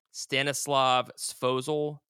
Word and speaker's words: Stanislav Svozil: Stanislav Svozil. Do you know if that name is right? – Sounds right.